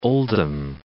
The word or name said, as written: Oldham